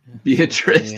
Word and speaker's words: Beatrice: No, I said Beatrice, Nebraska, right? – Beatrice, that's right.